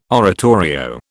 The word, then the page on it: oratorio: British English